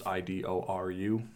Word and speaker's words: Idoru: So this rock star wants to marry a virtual reality pop star, and the second novel is called Iduru, which is how the Japanese would pronounce idol. So it's Idoru.